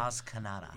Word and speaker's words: Maz Kanata: Maz Kanata.